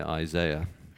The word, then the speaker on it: Isaiah: So if you have your Bibles open and you come with me to Isaiah chapter 5, and that's the right way to say Isaiah, by the way.